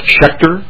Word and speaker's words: Schechter: It's Eric Schechter, S-C-H-E-C-H-T-E-R.